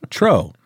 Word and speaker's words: Truax: Another new crusher, Kanem Truex, or is that pronounced Tro? – Yeah, Tro. – Tro? – Maybe. Is it E-A-U-X? – T-R-U-A-X. – Now, I don't know about that. That's not a French O sound.